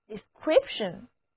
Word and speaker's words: description: 接下来我们把 de 前缀加在词根 script 的前面，最后再跟一个小尾巴 ion 这个名词后缀，得到的单词就是 description description。两个字母 i 和字母 e 统统都读作短的 e，重音在第二个音节 description description。